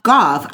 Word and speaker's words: Gauff: Also is that how you say it? Goff? – Goff. I would say that's Goff. – Can you spell it? – G-A-U-F-F.